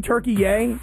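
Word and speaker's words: Türkiye: Listen, I liked saying Turkey Yay because I found this interesting that there was this new pronunciation of the country Turkey.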